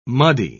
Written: muddy

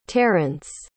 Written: terrence

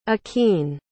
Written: aquene

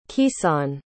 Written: keyson

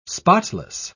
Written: spotless

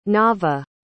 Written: naavah

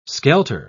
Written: skelter

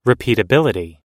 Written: repeatability

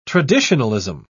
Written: traditionalism

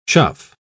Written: chuff

